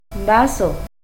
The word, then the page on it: vazoh: Náhuatl